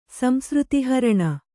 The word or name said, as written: ಸಂಸೃತಿಹರಣ